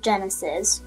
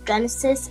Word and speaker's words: Jenisys: You can follow me on Instagram, Facebook, and TikTok on Pray2 Jesus with Genesis. Genesis is spelled J-E-N-I-S-Y-S.